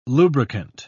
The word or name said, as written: lubricant